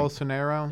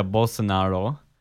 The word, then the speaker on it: Bolsonaro: Bolsonaro. – Yeah, Bolsonaro.